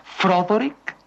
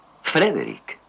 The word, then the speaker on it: Frederick: ¿Dice usted también Froderick? – No, Frederick. – ¿Y por qué no es Froderick Frankenstein? – Porque no, es Frederick Frankenstein.